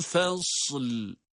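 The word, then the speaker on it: Fasl (فصل): İnnehu lekavlun fasl. – Bakınız, fasl. Sad harfi cezimle okunduktan sonra hemen lam harfini de cezimli olarak onun arkasına bitiştiriyoruz. Arada bir boşluk yok.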